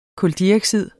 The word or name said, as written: kuldioxid